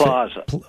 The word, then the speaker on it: PLOS: He's also an editor with the online research website, PLOS1. How do you pronounce that, Dennis? What's the best? – PLOS. I always say PLOS, but yeah.